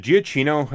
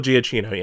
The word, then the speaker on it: Giacchino: Also, I just want to say, Michael, I think is it Giacchino, how you pronounce his name? – Or Michael Giacchino, yeah, who does the score for both this and Dawn.